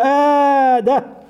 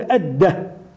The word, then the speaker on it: آدة: شراحيل بن آدة، وينطقه بعضهم فيقول أدة آدة، أبو حازم سلمة، أبو حازم سلمة بن دينار، وهذا كثير جدا.